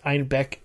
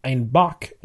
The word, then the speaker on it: Einbeck: Funny thing about the town's name, when the style was adopted by brewers in Munich, their Municher accents pronounced Einbeck as Einbock, which is German for billy goat.